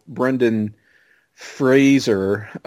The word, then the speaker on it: Brendan Fraser: Although you're doing much better with Brendan Fraser, is how I was told to say his name.